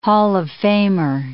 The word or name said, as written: Hall of Famer